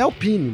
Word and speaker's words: Alpine: Sério que a pronúncia em francês é Alpine? Eu fui atrás, é Alpine mesmo, né? – Então é, vou começar a falar é.